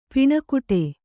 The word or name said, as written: ಪೆಣಗಾಟ